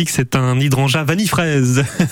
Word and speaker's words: hydrangea: Vous allez me dire, Martin, si je le prononce bien, hydrangea. Hydrangea. Hydrangea, oui, c'est ça.